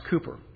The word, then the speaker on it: Cowper: It's spelled Cowper, C-O-W-P-E-R. It's pronounced Cooper, or as we Charleston boys would say, Cooper.